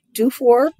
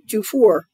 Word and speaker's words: Dufour: Well, there are only two ways to pronounce it, Dufour or Dufour.